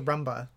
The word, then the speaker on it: Roomba: Sure, Roomba, okay.